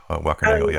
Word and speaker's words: Wackernagel: I can never get his name right, Wackernagel. – Wackernagel, yeah.